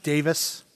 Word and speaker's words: Davies: It's pronounced Davis.